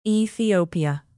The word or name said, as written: ethiopia